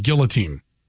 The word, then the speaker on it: guillotine: And then I still have to find out the correct pronunciation of guillotine. – Guillotine. – Is that what it is? – That'd be the French. Guillotine.